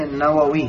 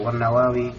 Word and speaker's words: Nawawi: Bacanya Nawawi gitu. Nawawi.